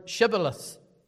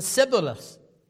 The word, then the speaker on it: Shibboleth: and they couldn't pronounce the H, just one letter. So the Shibboleth became the Sibboleth.